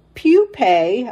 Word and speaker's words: pupae: That mulch is also perfect habitat for invertebrates that birds eat, including the pupae. I had to look this word up. It's P-U-P-A-E. I did not know that word. Pupae, it's pronounced. I had to look up the pronunciation.